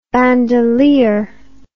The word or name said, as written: bandelier